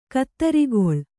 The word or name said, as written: ಕುದುರುಗೊಳ್